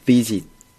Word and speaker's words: visit: 이걸 그냥 visit 이렇게 발음하지 마시고, 이 V를 그대로 발음하시면 돼요. – 네. – 좀 빨리 visit. – visit. – 앞에 V는 어떻게 발음하라고? – 아랫입술을 깨물어. – 그렇죠. 네. 살짝 내뱉듯이 마찬가지로. 해봅시다. 아랫입술을 살짝 깨물어 보세요. Visit. – Visit. – Visit. – Visit. – 그렇죠. 비지트가 아니에요.